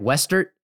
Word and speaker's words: Wester: It is with great pleasure I introduce to our listeners once again, Lexi Wester. Good to see you. – Good to see you guys too. Thanks for having me on. – Did I say your last name right? Wester, is that right? – Mm-hmm.